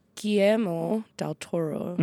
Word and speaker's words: Guillermo del Toro: Guillermo del Toro, I hope I'm pronouncing it right.